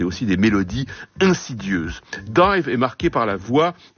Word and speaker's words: DIIV: Voici un autre groupe tout à fait digne d'intérêt, le New-Yorkais Dive, qui s'écrit bizarrement D-I-I-V, qui est peut-être plus apprécié chez nous encore que dans son pays.